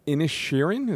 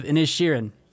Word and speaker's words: Inisherin: All right, so Banshees of Inisherin? Is that how you say it? – You got it, yeah, yeah. So, Banshees of Inisherin.